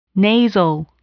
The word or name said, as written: nasal